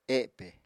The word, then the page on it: epe: Apatani